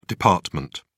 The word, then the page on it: dept: British English